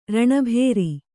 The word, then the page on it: ರಣಭೇರಿ: raṇa bhēri